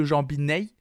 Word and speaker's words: Jambinai: Alors j'espère que je prononce bien. J-A-M-B-I-N-A-I. Donc je dirais plutôt Jean Binet.